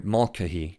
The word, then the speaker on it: Mulcahy: In this episode, Father Makahi, I hope I'm saying that right, Mulcahy, there you go, I probably said it right now.